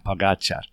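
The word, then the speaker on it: Pogačar: Now, someone told me today actually how to say his name. Pogacar. It's the accent on the second guy, Pogacar.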